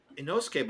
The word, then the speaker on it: Inosuke: Anyways, and then his sister, and then in Suke, or however you say it, in Suke, I can't even say it. The boar face guy, and then Zenitsu. – Inosuke. Watch me be saying it wrong this whole time. Like, I'm so confident. – Inosuke, but it's funny. I must have spelled it wrong because I spelled it Insouke. – I'm pretty sure it's Inosuke because dude would not stop saying it in the show.